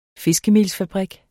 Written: fiskemelsfabrik

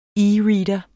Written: e-reader

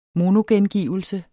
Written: monogengivelse

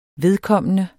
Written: vedkommende

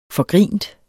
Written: forgrint

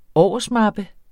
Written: årsmappe

